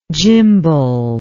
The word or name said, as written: gimbals